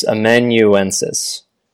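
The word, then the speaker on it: amanuensis: Before we go on, people have been complaining that you pronounce that word incorrectly. It's Emuensis. There are more syllables in it than you have been saying.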